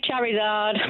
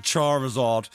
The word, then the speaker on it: Charizard: Yeah, it would help if you said it right. As everyone corrected us, it's a Charizard.